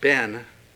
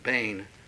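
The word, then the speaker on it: bane (בן): You might want to note the word son, in the Hebrew, bane, we oftentimes pronounce it ben, but it's probably best pronounced bane. – Was it spelled B-E-N? It's usually spelled B-E-N in the English, yeah.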